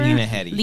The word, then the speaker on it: Lena Headey: Lena Headey. – Lena Headey, is that how you say it? – Yeah.